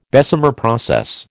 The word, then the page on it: Bessemer process: American English